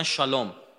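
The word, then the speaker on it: شالوم (שלום): عبری ها سه و شه تلفظ میکنن عمدتا موسی رو میگن مشه سلام رو میگن شالوم سلیمان میگن شلمان نسا زنا رو میگن نشا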